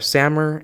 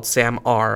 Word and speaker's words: SAMR: They are Sammer and TPACK. So Sammer, you'll also hear it called SAMR. I think both are acceptable pronunciations. I just call it Sammer.